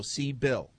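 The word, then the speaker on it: Sybil: Siebel. From France. And how do you spell that? – Well, it's S-Y-B-I-L, but she says it's pronounced S-E-E-B-I-L-L. So C-Bill.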